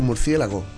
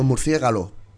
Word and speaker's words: murciélago: ¿Por qué dices tú murciélago? – Porque se dice así. – ¿Y por qué no decimos murciégalo? – Porque no se dice así.